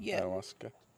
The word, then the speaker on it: Ayahuasca: Ayahuasca? Is that how you pronounce it? – Yeah. – Ayahuasca.